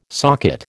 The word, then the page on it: socket: American English